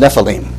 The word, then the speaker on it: Nephilim: That's pronounced Nephilim, and you transliterate it in the brackets.